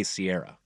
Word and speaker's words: Ciara: It's spelled C-I-A-R-A, but I think they say Sierra.